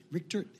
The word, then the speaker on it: Rickert: Joining me on my show today is a special guest, is Mr. Greg Richtert. – Rickert. – Rickert. Okay, it's just like it's spelled out.